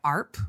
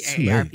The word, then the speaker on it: AARP: AARP, which is formerly known as the American Association for Retired Persons, but is now trying to go by ARP, which rhymes with harp,